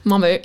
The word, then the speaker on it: Momo: Momo. – Momo.